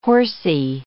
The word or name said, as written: horsey